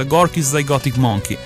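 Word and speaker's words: Gorky's Zygotic Mynci: Dopo gli Oasis abbiamo cambiato atmosfere musicali, ma siamo rimasti nella sezione i moderni con i, adesso li pronuncio, dimmi se sbaglio, Gorky Zygotic Monkey. – Sì, dovrebbe essere questa la pronuncia, Gorky Zygotic Monkey.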